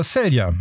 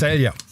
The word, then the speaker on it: Celje: Die Leverkusener gewannen ihr erstes Vorrundenspiel gegen den slowenischen Meister Celje mit 2:0. Und das waren die Nachrichten. Wetter und Verkehr jetzt mit dir. – Jetzt weiß ich auch, wie dieser Verein ausgesprochen wird. – Celje.